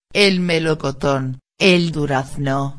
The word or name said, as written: el melocoton el durazno